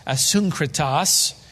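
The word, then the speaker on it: Asyncritus: Verse 14, greet Asunkrenas, Kritas, Asunkritas, which is the Greek pronunciation.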